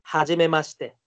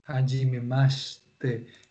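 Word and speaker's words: Hajimemashite (はじめまして): Hajimemashite. ¿Se fijaron? Hajime Hajimemashite. Fíjense que se escribe shi, hajimemashite se escribe. Sin embargo, Kojiro no pronunció la i. Él dijo hajimemashite. Él arrastró la SH.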